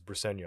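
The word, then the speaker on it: Briceño: There's an accent. – It's not on the website. That's why I had to ask Jonathan Long in class, like, how do you pronounce this? He goes, it's Bricenio.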